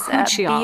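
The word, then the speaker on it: bakuchiol: Is it Bacuchiol? – Bacuchiol, yes. – Bacuchiol. – B as in boy, A-K-U-C-H-I-O-L, yes.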